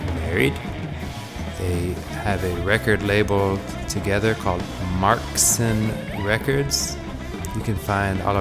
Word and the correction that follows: Marsken: No, I'm sorry, I said that wrong. Marsken. M-A-R-S-K-E-N. Marsken Records.